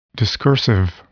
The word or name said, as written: discursive